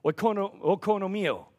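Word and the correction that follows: Oikonomia (οἰκονομία): How's that? Oikonomia is what it actually comes from.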